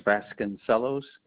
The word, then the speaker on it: Vasconcelos: Last name is Vasconcelos.